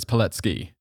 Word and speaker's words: Pilecki: They ended up getting quickly invaded, and a member of the Polish army was 38-year-old Captain Witold Pileski, who I'm probably going to pronounce wrong his name a lot in this one because I want to say Pilecki. – I'm pretty sure it's Pilecki.